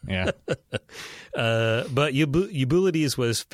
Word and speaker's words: Eubulides: It's Eubulides.